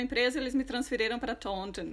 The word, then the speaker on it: Taunton: É Taunton.